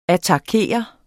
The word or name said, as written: attakere